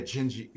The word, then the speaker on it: Dindi: So, yeah, Jinji. – Yeah, and it's weird because it's spelled D-I-N-D-I. – Right. – But in Portuguese, it's the D.